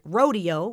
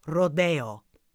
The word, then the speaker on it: Rodeo: Now, a quick note on the pronunciation. That is how music scholars refer to the ballet. It does come from a Spanish word. Copland occasionally called it rodeo when speaking casually, but for the record, the professional pronunciation for the concert hall is Rodeo.